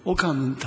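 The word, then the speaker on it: オカ ンヌン (옥한흠): まあ、私は団長として、行ったんですが、その時に、その主任牧師の奥先生、まあ、韓国の名前はリエゾンしますが、奥ハンフムって言うんですけども、オカンヌンと発音します。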